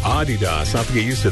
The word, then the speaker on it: Adidas: Did you know that most of us pronounce Adidas wrong? It's actually supposed to be Adidas. – Really? – Yeah, slight emphasis on the first syllable. Not Adidas. – Adidas. All right. – Yeah. That's German. It's a German company, so.